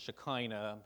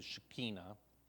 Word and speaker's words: Shekinah: And the Shekinah glory, some of us pronounce it Shekinah, but the Hebrew Shekinah, the Shekinah glory never leaves camp.